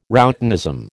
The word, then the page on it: routinism: American English